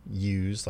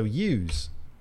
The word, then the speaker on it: Yews: It's spelled Y-E-W-S. – Because Yeezy is Y-E, right? – And where's the W then? – Well, if there was an S, it would be Yeez. And that doesn't rhyme with use.